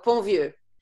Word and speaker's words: Pontvieux: Oh, Ponvieux.